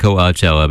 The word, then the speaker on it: Coachella: You know, I'm practicing saying Coachella because I've been mispronouncing it all these years. I've been saying Coachella, but it's that A sound that I need to eliminate. I'm going just for Coachella.